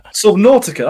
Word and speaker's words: Subnautica: It's called Subnautica, is it? – Subnautica, that's been out Subnautica.